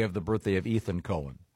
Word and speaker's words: Cohen: Leonard Cohen. – This gets confusing because... – No, not when it's spelled C-O-H-E-N. – It's definitely Cohen.